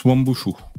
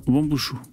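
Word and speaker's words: Wuambushu: Focus sur l'opération, alors comment ça se prononce ça Wambushu, euh, qui terrorise les populations du 101e département français. – Ça se prononce Wambushu. – Ah merci, Wambushu.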